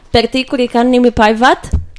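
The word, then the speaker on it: Pertti Kurikan Nimipäivät: Okay, so we were talking about a Finnish band called Pertti Kurikannimi Päivät.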